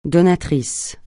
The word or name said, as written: donatrice